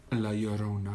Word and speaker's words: La Llorona: Poi ho scoperto, comunque sempre rimanendo a tema film, film che io non ho mai visto e non guarderò mai, anche La Lorrona. La, la, aspetta, pronuncia giusta? – La Llorona.